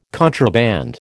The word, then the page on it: contraband: American English